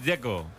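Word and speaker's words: Džeko: Dzeko. Bruno dice que es Dzeko. – Como tirando a J, Dzeko o algo así.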